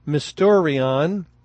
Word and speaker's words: mysterion: And in the Greek, you would pronounce it something like mysterion. It sounds very much like the English mystery. So mystery is basically a transliteration of the Greek word.